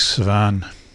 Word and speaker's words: Savane: Started off with Ali Fakature with his posthumously released album in 2006, Savane. I think it's pronounced that, S-A-V-A-N-E. I think it's pronounced that way.